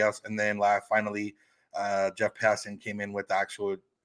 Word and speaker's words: Passan: Yeah, you can tell how exciting this is, because just pronounced Jeff Passan's last name right for the first time in history, in the history of this podcast.